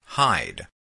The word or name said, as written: hide